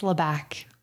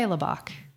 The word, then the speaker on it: Labac: It's Labac, but Labac. You can say Labac, either one.